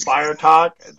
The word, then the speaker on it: FireXtalks: The X is silent hillbillies. So just Fire Talk.